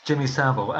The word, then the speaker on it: Savile: Jimmy Savile.